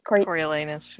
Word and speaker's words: Coriolanus: Coriolanus? – Coriolanus, yeah.